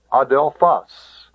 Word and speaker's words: adelphos: Ho adelphos. Notice the accent is on the last syllable.